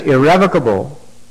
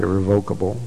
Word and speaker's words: irrevocable: As soon as irrevocable, that's the way that word is pronounced, by the way, not irrevocable, but irrevocable.